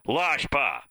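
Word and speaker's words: lâche pas: And he spells it L-O-S-H-P-A, which is really close. I mean, phonetically, that's exactly right. It's L-A-C-H-E and then P-A-S, Lashpa.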